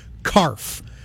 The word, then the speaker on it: CARF: And the license plate for the truck is actually CARF, C-A-R-F, which rhymes with barf.